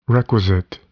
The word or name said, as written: requisite